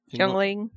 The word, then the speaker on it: Yuengling: Performs, the Yuengling Center. I said that right? – Yuengling.